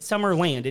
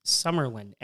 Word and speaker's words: Summerlin: Which isn't Summerland. It's Summerlin. S-U-M-M-E-R-L-I-N. – Yeah, it's like, Summerlin. – It's spelled L-I-N.